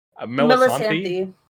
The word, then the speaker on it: Melisandre: I hope I'm saying this right. Melisandre. Melisandre. Yeah. How is it? – You got it.